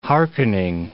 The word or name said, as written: hearkening